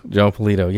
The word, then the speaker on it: Polito: The great Joe, is it Polito, I believe is how you pronounce it? Yes, Joe Polito.